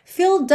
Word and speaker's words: filled: Aqui é super importante você realmente interiorizar, por exemplo, que filled você não pronuncia a letra E, porque senão não dá nem tempo. Se você for tentar falar filled up, filled up, não dá tempo. Esquece essa letra E, tá pintadinha de cinza, ela não é pronunciada, então você pensa em filled.